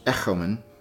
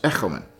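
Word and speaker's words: echomen (ἔχομεν): In ancient Greek, the omicron, which is the short o, and the omega, the long o, were pronounced alike. They were both pronounced o. So the first reading would be pronounced echomen, and the second one would be pronounced echomen, exactly alike.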